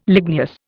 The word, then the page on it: ligneous: Indian